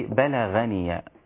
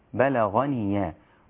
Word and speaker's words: بلغني: وقد بلغني، أنت بتقولي بلغني، غاء خطأ، غاء، وقد بلغ، الغين حرف مستعلي مفخم هكذا ينطق وهو مفتوح، بلغ بلغني، والغين من الحلق، لا نفعل، لا نأتي بها من الشفتين كما يفعل البعض فتصير هكذا بلغ خطأ. بلغ الغين موضعها ومخرجها الحلق، وليس هناك علاقة بين الحلق وبين الشفتين.